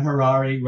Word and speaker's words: Harary: My name is Dan Harary, rhymes with Ferrari. That's how I remember how to say my last name.